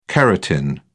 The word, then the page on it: keratin: British English